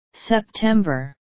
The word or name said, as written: september